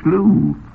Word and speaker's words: slough: S-L-O-U-G-H. It's pronounced slew.